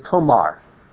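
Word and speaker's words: tomar (תמר): Now, the Hebrew word for date palm is tomar. Tomar. But it's a homophone for the Hebrew word tomar, which is pronounced exactly the same.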